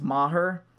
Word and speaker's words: Maher: which that's the correct way to say his name, it's Maher.